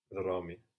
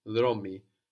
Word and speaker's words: δρόμοι: Δρόμοι. – Δρόμοι. And how is that E sound on the end spelled? – That's O-I. – O-I, good. Our plural E sound is spelled O-I.